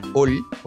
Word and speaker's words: Olle: Tú manejas a, un niño que se llama Ol, o se escribe Ole, O-L-L-E.